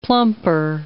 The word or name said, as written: plumper